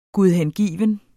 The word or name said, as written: GUDHENGIVENT